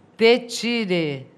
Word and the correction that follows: четыре: Tchê. A primeira letra é tchê, como o tchê dos argentinos e gaúchos.